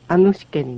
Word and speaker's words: アヌシケ: 音がつながって、アヌシケになります。 – アンの N とウシケの U がつながって、ヌと発音するわけですね。